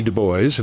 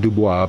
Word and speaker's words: Du Bois: Du Bois, and that's how he pronounced his name, not Dubois, but Du Bois.